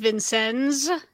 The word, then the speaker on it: Vincennes: July 3rd, 1988, the USS Vincennes. Thank you. – That's a lot of words, a lot of letters for two syllables.